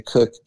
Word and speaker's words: Koch: My last name, we should probably tell people, is spelled K-O-C-H. So if you go to a an online distributor, you're going to want to search for Kathy Cook, K-O-C-H.